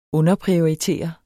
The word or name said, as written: underprioritere